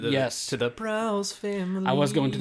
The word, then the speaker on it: Braus: Which is technically pronounced the Blouse family if you get into the real analytics mm-hmm. of the mm-hmm. story, which is just not very.